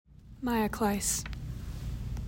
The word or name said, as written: Maya Clise